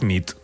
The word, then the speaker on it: nit: Y noche es nit. – Muy bien. Buena nit. – Buena nit, es verdad. – Buena nit.